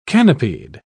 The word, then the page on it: canopied: British English